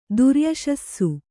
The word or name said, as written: ದುರ್ಯಶಸ್ಸು